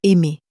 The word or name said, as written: aimer